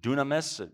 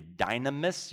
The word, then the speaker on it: dunamis: By the way, the Greek word behind power is dunamis. We pronounce it maybe dynamis. You can hear dynamite.